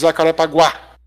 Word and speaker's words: Jacarepaguá: There is an accent on the last A, so it's Jacarepaguá. – Paguá. – The emphasis is on the end.